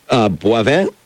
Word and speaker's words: Boivin: The Man Who Scared a Shark to Death, written by Chris Lombardo and Noel Boivin? – Yeah. – Am I saying it right? – Yes.